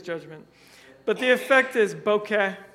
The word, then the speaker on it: bokeh: At least according to Webster's Dictionary, because they online they give a nice little pronunciation, which I checked, and it was bokeh.